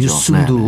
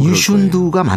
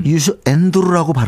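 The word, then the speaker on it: Youssou N'Dour: 유슌두가 맞는데 음. 유슈 엔두르라고 발음하는 경우도 있었어요.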